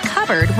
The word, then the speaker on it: cupboard: When we come back, why it's pronounced cupboard when it's spelled cup board?